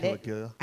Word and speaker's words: Cilicia: Cyrenians and Alexandrians and the Cilicia. That's what I when I read Cilicia.